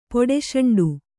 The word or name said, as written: ಪೊಡಸೆಂಡು